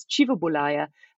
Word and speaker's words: Kivebulaya: And for any Luganda speakers out there, um, his name is often pronounced in Luganda as Chivabulaya, um, although Swahili and other um, East African language speakers tend to, tend to give it a hard K.